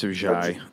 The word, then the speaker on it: Ajayi: So I see he made a move to pick up Ajayi. How do you say that name, by the way? – It's Ajayi.